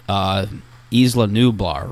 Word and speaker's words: Isla Nublar: Jurassic World, Isla Nublar, right? – Yes.